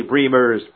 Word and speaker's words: Bremers: Don't Say You Don't Remember Beverly Bremers, pronounced like Dreamers.